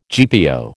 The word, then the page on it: GPO: American English